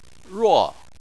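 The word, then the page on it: ruo: tone 4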